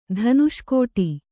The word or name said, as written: ಧನುಷ್ಕೋಟಿ